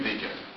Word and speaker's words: Wagen: Et Lacan donc fait bien remarquer que ce cheval, ce Wegen, est prononcé à la viennoise, la Wegen donc renvoie à Wagen, qui est le wagon, le chariot, et Wagen lui-même est prononcé avec le tréma, c'est-à-dire est prononcé Wegen.